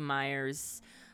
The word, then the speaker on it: Myers: Myers.